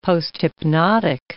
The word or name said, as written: posthypnotic